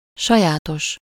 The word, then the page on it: sajátos: /ˈʃɒjaːtoʃ/